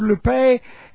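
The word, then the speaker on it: lupe (λύπη): Now, the word sorrow is the word lupe, and L-U-P, long E.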